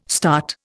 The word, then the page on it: START: Indian